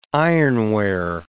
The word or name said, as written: ironware